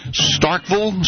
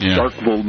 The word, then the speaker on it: Starkville: it's Starkville, Mississippi, where Starkville. – The campus of Mississippi State is. – They drop that vool and make it, call it Starkville.